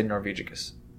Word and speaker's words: Norvegicus: Yeah, I've heard it pronounced way too many ways, but I always say Norvegicus. But yeah. – Norvegicus. – That's the one.